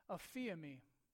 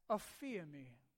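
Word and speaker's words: aphiami (ἀφίημι): It's actually pronounced aphiami. Say that with me. Aphiami.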